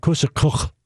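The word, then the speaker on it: còsagach: It's C O S A G A C H. It's like kusakuk.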